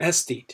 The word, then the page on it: aesthete: American English